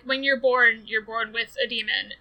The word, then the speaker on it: daemon: what's called a demon. It's spelled D-A-E-M-O-N.